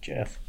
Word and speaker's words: Geoff: Probably Jeff. – Does that look like Jeff to you? – Where at? That's pronounced Jeff.